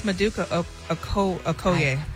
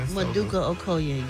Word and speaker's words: Maduka Okoye: Okoyoke? O-K-O-Y-E? – Oh, my God. – Could anybody say it right? – I think it's Maduka Okoye. – No idea. – Well, they should break it down. – Maduka Okoye, Gary.